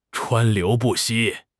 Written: 川流不息

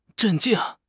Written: 镇静

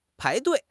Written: pai2 dui4

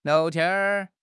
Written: liao2 tian1

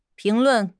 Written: 评论

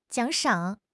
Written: 奖赏

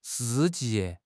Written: shi4 ji4

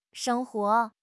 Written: sheng1 huo2

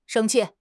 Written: sheng1 qi4

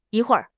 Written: yi1 hui4 r5

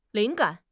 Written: 灵感

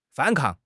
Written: 反抗

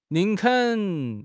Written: 宁肯

